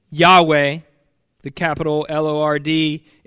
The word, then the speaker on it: Yahweh: his name which we pronounce from the Hebrew Yahweh. Formerly, it was oftentimes pronounced Jehovah.